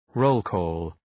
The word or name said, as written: roll-call